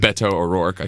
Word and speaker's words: Beto O'Rourke: With Beto O'Rourke.